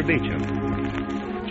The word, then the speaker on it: Beauchamp: The main Dr. Beecham office is at 5th and Broadway in downtown Los Angeles, spelled B-E-A-U-C-H-A-M-P, pronounced Dr. Beecham.